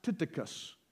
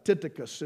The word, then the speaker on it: Tychicus: Fellow that we don't know that much about, but you pronounce his name, it's pronounced, most people mispronounce it, but it's pronounced Tychicus, would be kind of the way you would say it. Tychicus, and it really would be almost Tychicus, would be the way it would be pronounced.